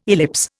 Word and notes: ellipse: Indian

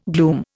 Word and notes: gloom: Indian

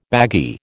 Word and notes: baggy: American English

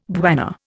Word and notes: bwana: Indian

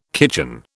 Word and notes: kitchen: British English

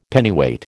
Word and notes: Pennyweight: American English

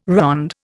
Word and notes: ronde: Indian

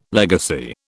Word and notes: legacy: British English

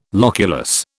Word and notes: loculus: British English

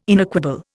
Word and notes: inequable: Indian

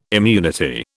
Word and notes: immunity: British English

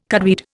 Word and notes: cudweed: Indian

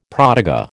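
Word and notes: protégé: American English

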